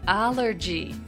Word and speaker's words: allergy: アレルギーの英語の発音は allergy というんですね